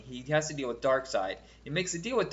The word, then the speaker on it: Darkseid: Oh, no, it is Darkseid.